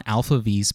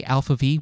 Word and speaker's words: ALPHV: and how to pronounce Alpha V, which is actually spelled A-L-P-H-V? I'm trying my best here, folks.